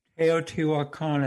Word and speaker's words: Teotihuacan: Teotihuacan, as a matter of fact, Teotihuacan was the sixth largest city in the world in the 6th century.